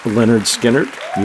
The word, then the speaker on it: Lynyrd Skynyrd: Well, congratulations to Lynyrd Skynyrd, pronounced Lynyrd Skynyrd.